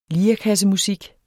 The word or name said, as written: lirekassemusik